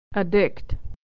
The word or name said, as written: to addict